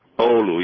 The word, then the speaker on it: Oulu: Oulu, yes. O-H-L-O would be L-O-O, Lou for, it was the second part, but O, as you spelled O-H, you know.